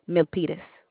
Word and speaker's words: Milpitas: Milipetus, California. And Milipetus. – M-I. – Mm-hmm. – Go ahead. – L-P-I-T-A-S. – You spelled it, you pronounced it real well. The other little girl, she said, I can't pronounce it. So it's M-I-L-P-I-L-A-S. – T-A-S, Milpitas. – Okay, M-I-L-P-I-T-A-S? Or yes, ma'am. – A P and a T, okay. N-I-L-P-I-T-A-S.